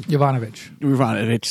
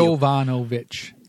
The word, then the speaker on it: Yovanovitch: Yovanovitch. – Thank you. – Yovanovitch. Like Yankovic.